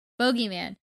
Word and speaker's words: bogeyman: Boogeyman. Boogeyman? Boogeyman. Boogeyman. It's spelled bogey, but I think it's boogie. – With an umlaut? – No, like bogey, B-O-G-E-Y.